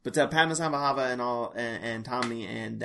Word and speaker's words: Padmasambhava: I think it's Padmasambhava. – I think you're saying it right. – The abbot and Tomney and this mysterious Padmasambhava.